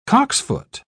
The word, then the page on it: cocksfoot: British English